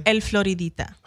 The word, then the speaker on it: El Floridita: El Floridita. That's how you spell it. How you pronounce it.